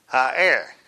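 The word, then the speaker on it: ha'er (ἀήρ): Aer. Now, it's actually spelled A-E-R. A-E-R. And the E has a hyphen above it. and it's pronounced as a two-syllable word, ha'er.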